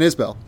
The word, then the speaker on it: Isbell: For those who don't know, the artist Jason Isbell said, I get that wrong? – No, it's Jason Isbell. Yeah, it is.